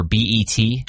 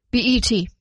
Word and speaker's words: BET: Am I saying it right? – BET. – It's not Bet? – I don't think so.